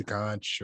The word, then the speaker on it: conch: They all wanted the conch, or conch is the correct way to say it, but everyone says conch colloquially.